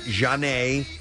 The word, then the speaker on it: Zhane: And if you would like to take a look at her social media accounts on Instagram, she is at Janae. EXO, and Janae is spelled Z-H-A-N-E, and then EXO.